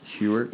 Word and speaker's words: Heuertz: Heuertz.